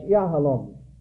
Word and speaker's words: yahalom: I should have mentioned the diamond Hebrew word, that's yahalom. The accent more on the last syllable, representing or meaning hardness.